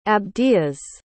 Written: abdias

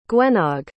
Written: gwennog

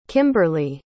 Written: kimberli

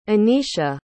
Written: Aneisha

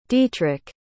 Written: dietrich

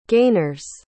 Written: gainers

